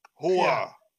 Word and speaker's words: Hoar: Hoar. – It's H-O-A-R. – It's spelled like whore, but it's pronounced how Al Pacino would say Hoar.